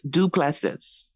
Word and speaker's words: Duplessis: So it's Duplessis. Duplessis. – D-U-E? – P as in Paul.